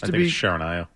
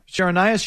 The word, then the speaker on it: Charania: It's Charania. – Charania, Shams Charania.